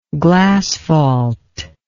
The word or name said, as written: glasphalt